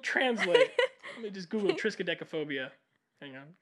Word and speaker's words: triskaidekaphobia: The origin that I found was the word for the fear of the number 13 is known as triskaidekaphobia. Triskaidekaphobia. Triskaidekaphobia. Tris, like 3. Triskaidekaphobia. – Is that Trace or something? – Well, it's spelled T-R-I-S-K-A-I-D-E-K.